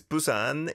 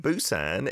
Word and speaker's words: Busan: You can still find it from time to time, spelled P-U-S-A-N, Busan. And a B, I suppose, is it's closer to a B, I would say, in terms of the sounds, but it's not a million miles away from a P either, depending on which vowel sound it is paired up with. But yeah, the P, like in Pita, that is closer to a P, I would say, overall.